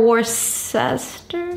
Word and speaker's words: Worcester: Is it Worcester or Worcester? – Worcester. – Worcester. – Worcester. – Okay, hold on. Worcester. Like that? – Yeah. – Worcester. – Yeah, it's one of those weird British pronunciations.